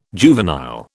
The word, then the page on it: juvenile: British English